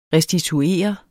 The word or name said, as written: restituere